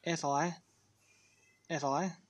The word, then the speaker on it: Rififi: Rafifi. – Yeah. – Rifi. FI. Yeah. – You sure it's not called Rafiki? – No, it's Rififi. – Nothing's coming up in Letterboxd, though. – Hang on. Yeah, R-I-F-I-F-I.